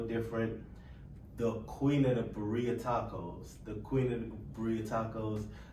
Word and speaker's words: Birria: You know, gotta say it right. It's the Birria Queen.